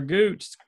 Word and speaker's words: Gooch: His last name rhymes with Hooch. – Oh, Gooch. – Taylor Gooch.